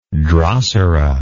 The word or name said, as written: drosera